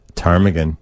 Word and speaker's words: ptarmigan: Ptarmigan. – Where letters appear in word spelling, but not in its pronunciation.